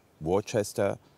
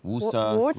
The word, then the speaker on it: Worcester: Eigentlich wäre das doch die Challenge, wie spricht man es richtig aus? Worcester. – Worcester.